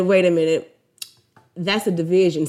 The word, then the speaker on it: DVSN: And honestly, I didn't even know that their name was Division at first, because it's spelt DVSN. So that's what I was saying. I was saying DVSN.